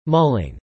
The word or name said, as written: mulling